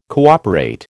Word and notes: cooperate: American English

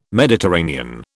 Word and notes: Mediterranean: British English

